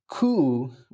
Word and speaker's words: Koo: Yeah, it's a coup, which is spelled like K-O-O and not like C-O-U-P.